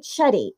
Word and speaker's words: Shetty: So everyone's probably going, you know, he said, how do you say this? Jay Shetty. Is that the right? – Yeah. S-H-E-T-T-Y. – So do you say it with an S or Shetty? Is it Shetty? – Yeah, oh, Shetty.